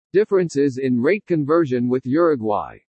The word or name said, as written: DIFFERENCES IN RATE CONVERSION WITH URUGUAY